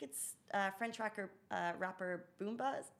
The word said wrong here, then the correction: Booba: Oh my God, I've said the name wrong. Excuse me. Booba? – Booba, Booba is coming.